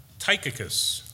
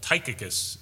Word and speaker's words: Tychicus: Tychicus, that's how you say it, Brian. Sorry about that. That was a rough one. I said it wrong all week. in my own mind, but Tychicus is how you say that name.